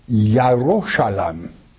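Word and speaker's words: Yaroshalam (ירושלים): You don't pronounce it Jerusalem. In the manuscripts, it is Yaroshalam.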